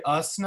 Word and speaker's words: Gus: It's actually us now. The G is silent.